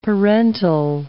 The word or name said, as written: parental